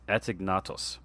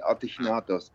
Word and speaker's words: Atignatos: It's Atignatos. Is that close? – Yeah, very much, actually. The only thing is that in Gaulish language, the G is pronounced as a... So it's actually Atignatos.